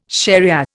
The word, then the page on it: shariah: Indian